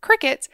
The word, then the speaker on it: Cricut: I am working off an outline here, and it is impossible for me to read this name and not want to say Cricut, but I'll do my best to pronounce it the correct way, which is Cricut,